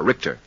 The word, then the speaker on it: Rickter: It's spelled R-I-C-K-T-E-R, Richter.